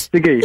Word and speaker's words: Sigrid: How do you say Sigrid? – Sigrid. – Yeah. Sigrid.